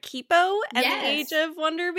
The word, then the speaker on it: Kipo: It is Kippo. You got it right. Most people say Kippo.